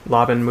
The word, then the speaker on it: Laban: and I found out that for 3 years I'd been pronouncing Laban incorrectly. So that was... – Because you called it Laban for a while, Laban, right? – When I was taught Laban for conductors, it was taught to me as Laban. And so that whole episode, I was just like, don't say Laban.